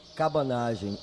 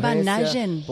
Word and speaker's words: Cabanagem: Gracias por el paréntesis histórico de entender que fue el cómo se pronuncia, perdónante. – Cabanagem, em decorrência. Cabanagem. Espera, decilo mais despacio.